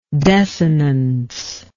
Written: desinence

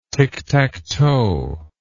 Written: tictactoe

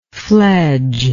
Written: fledgeless

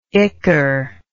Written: icker

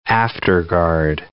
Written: afterguard

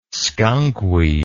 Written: skunkweed

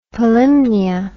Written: Polymnia

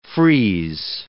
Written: freeze

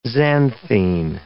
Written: xanthene

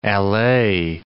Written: allay

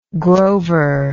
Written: grover

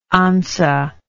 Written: anza

